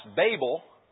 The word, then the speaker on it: Babel: The word is actually pronounced Babel, but for being used to its sake, I'll say Babel as well. But it's a long A there, actually, Babel.